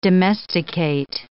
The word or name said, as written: domesticate